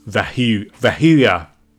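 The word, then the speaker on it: Vehuiah: And this produces the first angel's name, which is Vav-Heh-Vav, or it's pronounced as Vehuya, once the additional suffix is added, which we'll discuss later.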